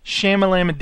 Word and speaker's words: Shyamalan: Night Shyamalan Ding Dong coming out called Lady in the Water.